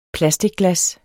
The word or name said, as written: PLASTICGLAS